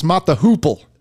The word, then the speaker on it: Mott the Hoople: Is it Mott the Hopple? – Yeah. – Wow. – I'm sorry. He mispronounced it's Mott the Hoople.